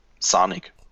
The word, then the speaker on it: Čanik: Sanik ist Englisch ausgesprochen, frag mich nicht. Čanik wäre völlig korrekt, wenn er den slawischen Vornamen so richtig aussprechen würde, ja, aber Sanik wird er ausgesprochen anscheinend.